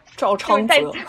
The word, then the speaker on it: 赵昌哲: 然后那个赵赵仓哲，哎呀，我这个发音真的对我来说好难呀。 – 赵昌哲，对，在车上的时候，然后他听到了听到了这个熟悉的歌声。